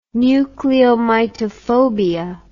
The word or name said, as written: nucleomitophobia